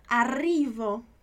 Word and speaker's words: arrivo: Essa pronúncia do RR. Arrivo. Tudo bem? É sempre esse R bem pesado, vamos dizer assim.